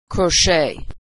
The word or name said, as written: crochet